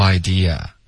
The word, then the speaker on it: idea: I had no idea. – I had no idea. – OK, 然后注意 idea, 结尾的啊千万不要加儿化音。 – 不要读成 idea。 – 对没有 dear。从来没有 dear。